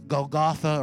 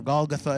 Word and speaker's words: Golgotha: We've been there to this Golgotha, or Golgotha as some pronounce it,